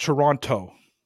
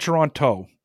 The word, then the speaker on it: Toronto: So it's spelled T-O-R-O-N-T-O, right? – Mm-hmm. – And Americans call it Toronto. – Mm-hmm. – It's not Toronto. It's Toronto. – Toronto. – Toronto. You don't pronounce the T. It's Toronto.